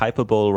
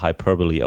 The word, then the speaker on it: hyperbole: Im Grunde der erste Satz schon, think hyperbole rhymes with superbowl und das wird aber hyperbole ausgesprochen, was komisch ist, aber ist nur mal so.